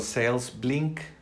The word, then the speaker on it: SalesBlink: Eh, puedes entrar a enlac.ee e diagonal salesbrink. Digo salesblink, se escribe s a l -E s b l i n k y bueno, puedes entrar a enlac.ee e diagonal sales blink para aprovechar esta aplicación y oferta.